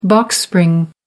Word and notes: box spring: American English

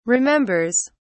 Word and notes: remembers: British English

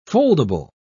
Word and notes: foldable: British English

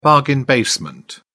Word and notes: bargain basement: British English

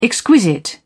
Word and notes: exquisite: British English

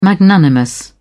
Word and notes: magnanimous: British English

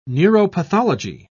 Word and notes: neuropathology: British English

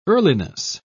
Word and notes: earliness: British English

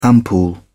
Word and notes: ampoule: British English

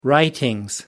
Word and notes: writings: British English

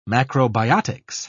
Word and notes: macrobiotics: British English

